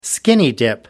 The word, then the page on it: skinny-dip: American English